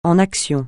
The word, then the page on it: en action: francais